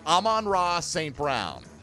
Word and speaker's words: Amon-Ra St. Brown: Here's how you say it for folks who need to know. Amon-Ra St. Brown.